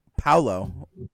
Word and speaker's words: Paolo: I'm sorry. It's Paolo. – It's just Paolo.